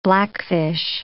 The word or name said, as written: blackfish